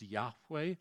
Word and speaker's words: Yahweh: Lord there translates the Hebrew four letters, YHWH, pronounced Yahweh. God's name is not Jehovah. They don't have a J or a V in their alphabet. The Jews could not have physically said the word Jehovah.